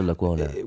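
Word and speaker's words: Lecuona: And how do you say, is it Lacona? – Ernesto Lacona.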